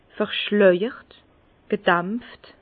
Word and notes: verschleijert, gedämpft: Bas-Rhin; Herrlisheim

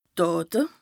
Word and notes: Dàte: Bas Rhin

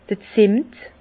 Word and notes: d'r Zemmet: Bas Rhin; Herrlisheim

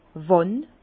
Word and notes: wann?: Bas-Rhin; Herrlisheim

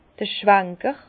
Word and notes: d'r Schwanker: Bas-Rhin; Herrlisheim

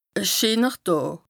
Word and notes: e scheener Dàà: Bas-Rhin; Strasbourg

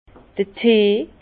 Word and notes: d'r Tee: Bas Rhin; Herrlisheim

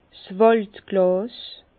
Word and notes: 's Wàldglàs: Bas-Rhin; Herrlisheim

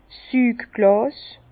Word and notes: 's Sügglàs: Bas-Rhin; Herrlisheim

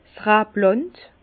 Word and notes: 's Rablànd: Bas-Rhin; Herrlisheim

